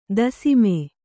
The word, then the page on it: ದಶಮ: daśama